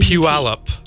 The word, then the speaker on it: Puyallup: Puyallup. – Puyallup.